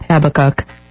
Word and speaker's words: Habakkuk: Oh, well, there's a couple different ways, but I'm going to go with Habakkuk.